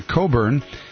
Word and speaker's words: Cockburn: It's kind of Scottish or something, but it's spelled C-O-C-K-B-U-R-N, but it's pronounced, thankfully, Coburn. I actually heard him on a show one time, and they pronounced it the way it looks like it's spelled, you know, which sounds like something would happen.